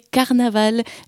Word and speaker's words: carnaval: En occitan, ça s'écrit comme en français, carnaval, mais ça se prononce carnaval. Suffit de faire le V en be.